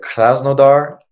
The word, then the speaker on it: Krasnodar: And Sevilla takes on, let me pronounce this right, Krasnodar.